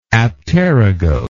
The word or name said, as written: apterygote